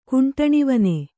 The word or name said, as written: ಕುಂಟಣಿವನೆ